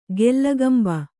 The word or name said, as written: ಕೀಲ್ಕಂಬ